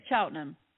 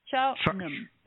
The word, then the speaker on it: Cheltenham: We say Cheltenham. Cheltenham. Cheltenham. That's how we pronounce it.